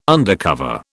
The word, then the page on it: undercover: British English